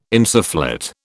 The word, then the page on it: insufflate: British English